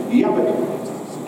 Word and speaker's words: Jabbok: Now in Hebrew, Jabbok, as it would be pronounced with a Y instead of a J, Jabbok, is very similar to Jacob.